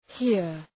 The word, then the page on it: hear: hıər